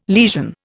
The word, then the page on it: lesion: Indian